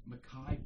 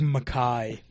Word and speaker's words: Mekhi: Mekhi Becton. Oh, beautiful player. – So sad. – Oh, yeah, so sad. I saw this. – And you said his name wrong. Big Mekhi.